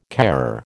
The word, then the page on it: carer: American English